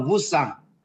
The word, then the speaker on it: VUSAN: 是，来先问一下我们的两位老师哈，请问一下，这个你们这个课后班哈的名字很特别，它的拼音是 VUSAN，这个发音要怎么发音？先教我好不好？ – 这叫 Wusang。